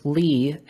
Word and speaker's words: Leigh: My middle name, Lee, actually, and it's Lee spelled the fancy way, L-E-I-G-H, was just something that like I've always just really liked the way that it's spelled.